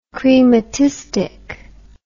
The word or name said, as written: chrematistic